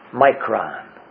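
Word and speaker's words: micron (μικρόν): The phrase, the English phrase, a little while, comes from one Greek word, which is pronounced micron.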